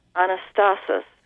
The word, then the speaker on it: anastasis: For instance, Philippians 3:10, Paul says, that I may know him and the power of his anastasis, if I'm pronouncing that correctly. – Yes, you are.